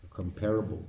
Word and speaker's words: comparable: Comparable, people say. Comparable is the right pronunciation.